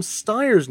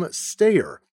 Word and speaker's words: Steyer: Yesterday, I pronounced Tom Steyer's name as Tom Steyer. I deeply regret the error, which I attribute to my training in the German language.